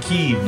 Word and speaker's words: Kyiv: They're all calling him Kyiv now.